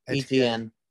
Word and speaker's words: Etienne: Etienne Jr.? – Etienne. He's French.